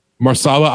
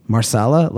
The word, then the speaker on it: Mahershala: Marsala? Like, okay. – Yeah. It's spelled Mahershala, but I think pronounced it's Marsala.